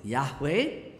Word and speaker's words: Yahweh: that's just Y-H-W-H, you cannot even pronounce it, but because we need to have it pronounced, so they added the letter at the A, so it's Yahweh.